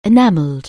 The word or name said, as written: enactment